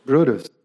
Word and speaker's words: Brutus: In order to save the republic, Gaius Cassius Longinus and Marcus Junius Brutus, I don't know how to pronounce his name, Brutus? Brutus, yeah, you got it.